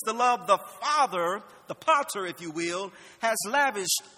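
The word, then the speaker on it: pater (πατήρ): Friends, the word father in the Greek is pronounced pater.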